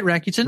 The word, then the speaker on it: Rakuten: So how do you pronounce that? – I think you got it right. Rakuten.